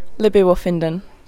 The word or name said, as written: Libby Wooffindin